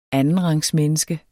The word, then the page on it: andenrangsmenneske: [ ˈanənʁɑŋs- ]